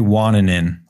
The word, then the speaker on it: Wananen: I'm Carrie Wananen. It's a hard one. It's got a lot of A's and N's.